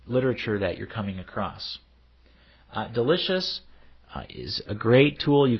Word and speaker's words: del.icio.us: It's spelled del.icio.us, but pronounced Delicious.